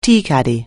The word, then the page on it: tea caddy: anglais